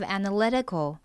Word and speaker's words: analytical: Okay, and next we have analytical. 重音在中间 L 的发音位置 Analytical.